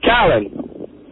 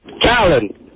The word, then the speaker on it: Callen: It's Callan. – It's what? – Callan. – Callan. – Callan? – Callan. – Callan. – Spell that name for us, please. – C-A-L-L-E-N. – Callan. – Well, Joe Ardinger wrote A-L-A-N, but it's too late to fire him now.